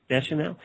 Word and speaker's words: Deschanel: Deschanel.